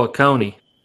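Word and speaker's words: Ocoee: Owasi? What is it, the one that's below? It's like 30 minutes south of me. I'm in Cleveland right now. – Oh, Oconee.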